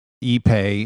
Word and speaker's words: ipe: That's spelled I-P-E. It's pronounced I-P-E. ePay, yes.